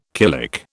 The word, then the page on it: killick: British English